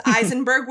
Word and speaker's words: Aisenberg: I have an easily mispronounceable last name because it's Eisenberg with an A. Who would look at the name Aisenberg and think Eisenberg?